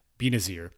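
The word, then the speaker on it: Binazir: I'm not really good with pronunciation, but it's B-I-N-A-Z-I-R, Benazir.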